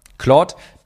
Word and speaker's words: Claude: Claude, damit ist die künstliche Intelligenz gemeint, falls ihr noch nicht kennt, Claude geschrieben, halt französisch ausgesprochen, Claude. Claude.ai.